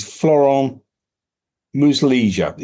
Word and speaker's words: Florent Muslija: So as far as Dembele is concerned, the first player we came up with is, and you're going to need to help me with some of these pronunciations as always, is Florent Muslija is the best I can do. – I think that's pretty decent, actually. Yeah, I'm pretty happy with that.